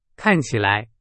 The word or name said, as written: 看起来